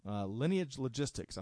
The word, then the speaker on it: Lineage Logistics: First off, as reported by the Wall Street Journal, Lineage Logistics, I think I'm pronouncing that right.